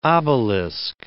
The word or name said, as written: obelisk